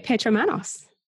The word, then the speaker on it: Petra Manos: I say Petra Manos.